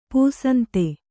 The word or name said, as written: ಪೂಸಂತೆ